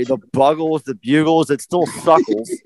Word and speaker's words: Buggles: There's two G's in there. It's not the Bugles, it's the Buggles.